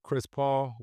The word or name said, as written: Chris Paul